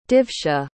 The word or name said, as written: divshah